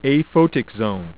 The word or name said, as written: aphotic zone